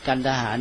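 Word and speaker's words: Kandahar: คริสต์ศักราชนะอันนี้ก็ในทางประวัติศาสตร์ในทางการสงครามก็เมื่อกี้นี้พูดถึงเอาย้อนไปก็มีผู้ที่มาที่ใช้เส้นทางนี้ชัดก็มีใครหนึ่งอเล็กซานเดอร์มหาราชอเล็กซานเดอร์มหาราชนี่มาถึงเมืองกันทหารที่เราเรียกกันดาห่าเนี่ยที่วิทยุเรียกกันดาห่าเราอาจจะออกเสียงเป็นกันทหารก็ได้กันดาห่าเนี่ยอเล็กซานเดอร์มาถึงที่นี่ก่อนมาแล้วก็เป็นผู้สร้างเมืองนี้นะประวัติกันดาห่า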